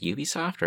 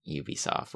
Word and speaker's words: Ubisoft: I'm already saying Ubisoft with a Y anyway. – I think that is how it is.